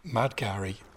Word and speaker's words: Mad Gary: Mad Gary rhymes with Cadbury, so all the plays have a chocolate bar title.